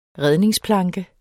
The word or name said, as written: redningsplanke